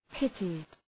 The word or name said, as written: pitted